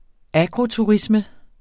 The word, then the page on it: agroturisme: ˈagʁo-